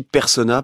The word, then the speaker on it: Personnaz: Et alors, normalement, on dit Persona parce que c'est un nom à consonance savoyarde. – Ouais. – Et donc, comme Avoria ou la Clusa, on prononce pas le Z.